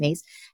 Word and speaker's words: Myss: Her last name is spelled M-Y-S-S, but it's pronounced Mace.